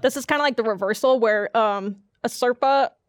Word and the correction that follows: Sherpa: Is that how you say it? – Sherpa? – Yeah.